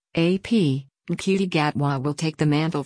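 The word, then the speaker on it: Ncuti: Nkuti dazzled us, seized hold of the Doctor and owned those TARDIS keys in seconds. Gatwa, whose first name is pronounced Shuti,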